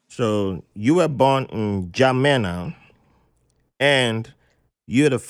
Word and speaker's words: N'Djamena: So, N'Djamena, the N is silent.